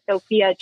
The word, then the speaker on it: Sophia: My name is Sophia Johnston, S-O-P-H-I-A, J-O-H-N-S-T-O-N.